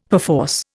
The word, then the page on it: perforce: Indian